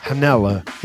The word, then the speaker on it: Janela: Janella. – Janella. Like it's like jalapeno. How Ricky Trailer Park Boys pronounces it.